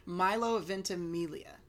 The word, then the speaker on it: Milo Ventimiglia: Milo Ventimiglia.